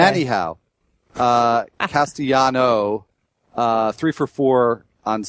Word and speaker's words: Castellanos: I'm happy to share the thunder because you talked about Nick Castellano, who you pronounced that way. – According to Baseball Reference. – According to Baseball Reference, yes. – There's no S on the end. – I was going to go Castellanos. There is an S on the end, but you don't say it.